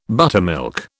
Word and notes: buttermilk: British English

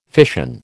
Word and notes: fission: American English

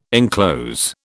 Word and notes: enclose: British English

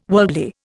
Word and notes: worldly: Indian

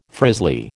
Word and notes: frizzly: American English

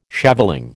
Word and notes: shaveling: American English

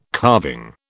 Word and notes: carving: British English

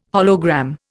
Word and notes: hologram: Indian

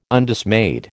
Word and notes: Undismayed: American English

